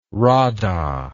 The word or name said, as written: radha